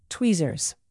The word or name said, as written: tweezers